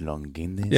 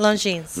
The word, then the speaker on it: Longines: Longines. – Yeah, how do you say that? – Longines.